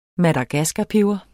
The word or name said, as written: madagaskarpeber